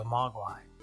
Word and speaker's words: Mogwai: However, Gizmo, being the curious Mogwai that he is that how you pronounce that? Mogwai? – Yeah, Mogwai? – I think so, yeah.